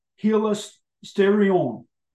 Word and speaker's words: hilasterion: The Greek word used here for propitiation is hilasterion.